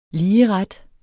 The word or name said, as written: ligeret